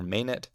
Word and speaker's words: Manet: It's spelled M-A-N-E-T, okay? It should, like, it looks to me like it should be Manet or Manet. And it is pronounced, the A is pronounced like ah, like the word rot, ah. So, Manet.